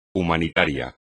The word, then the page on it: humanitario: espagnol